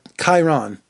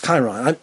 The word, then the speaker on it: Chiron: I got his name wrong. Chiron. That's what it is. Chiron. People were emailing me. I got the name wrong, and they really blambasted me. I should have looked that up. It's Chiron. I got it this time.